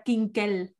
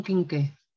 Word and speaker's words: quinqué: Pero tú, ¿cómo pronuncias la palabra kinkel? No dices kinkel. Dilo bien. En andaluz. – En andaluz, un quinqué. – ¿Un quinqué? – Un quinqué, eso es muy, muy andaluz.